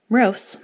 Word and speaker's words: Mrose: Mrose, my middle name, spelled M-R-O-S-E, is a Polish last name from my mother's side of the family. It's pronounced like gross, with an mmm. Risi, my last name, spelled R-I-S-S-I,